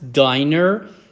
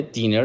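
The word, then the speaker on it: diner: داینر یعنی رستوران و غذاخوری ساده. دینر لطفا تلفظ نکنید، دینر با دابل ان هست.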